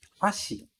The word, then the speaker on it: hashi: E esse X do final, que é escrito S-H-I, ok? Hashi. Aqui no Brasil, a gente costuma falar hashi para chamar esses palitinhos, mas a pronúncia mesmo em japonês é hashi, hashi, com a ênfase lá no ha e não no shi, certo?